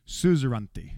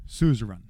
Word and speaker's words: suzerainty: When you did this Hittite deal, when you did it, the deal was uh, it, uh, let me see, make sure I say this correctly. This word is an odd word. Suzerainty, suzerain. Some people would say suzerain.